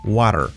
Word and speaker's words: water: No es water, es wa, wa, water, water, water, when, what.